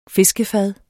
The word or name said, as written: fiskefad